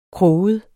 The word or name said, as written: kroget